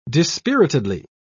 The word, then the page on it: dispiritedly: British English